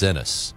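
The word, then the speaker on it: Enes: Is his name pronounced Ennis or Enos Cantor Freedom? – I believe it's Ennis.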